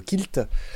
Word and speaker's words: quilt: Alors quilt, on le prononce quilt. Mais bien sûr, j'avoue être, j'avoue, oui, j'avoue être grand fan du quilt et même dans sa prononciation francophone, le kilt, je trouve ça très bien.